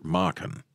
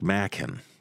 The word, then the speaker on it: Machen: and I have been calling the decadent Welsh mystic who wrote The White People, Arthur Machen, rhymes with poppin' and lockin'. But our listener said it's more like Machen,